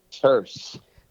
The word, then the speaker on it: Terse: Terse. – There you go. – It's a curse with a T.